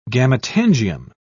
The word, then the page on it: gametangium: British English